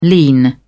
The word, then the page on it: lean: British English